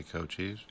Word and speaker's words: Cochise: Audio Slave with Cochise. I'm sure I'm not saying that properly. – Cochise? – Is that how you say it? – I would say Cochise.